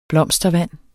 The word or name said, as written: blomstervand